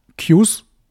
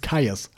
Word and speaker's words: Kyuss: Auf einmal hatte ich irgendwie mega Bock auf äh, Kaius, also Qs für, für diejenigen, die wie ich gedacht haben, dass das Qs ausgesprochen wird, also K-Y-U-S-S, aber es ist Kaius, habe ich dann später erfahren.